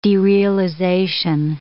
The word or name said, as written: derealization